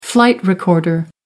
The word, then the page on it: flight recorder: American English